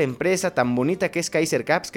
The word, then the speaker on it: Kaiser Caps: Ya lo saben, Facebook e Instagram, ahí aparecen Kaiser Caps, Kaiser este, con K eh, acento en la A y caps, como gorras en inglés, ¿no?